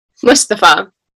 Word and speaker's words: Mustafa: Mustafa.